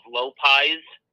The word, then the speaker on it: Lopiez: Side note, next time you can get a different place in the QC, it's called Low Pies Pizza. I think it's spelled L-O-P-I-E-Z.